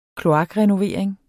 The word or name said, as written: kloakrenovering